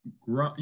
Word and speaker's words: Groening: Matt Groening, is that it? – Is it Groening? – Groening.